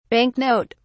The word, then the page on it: bank note: British English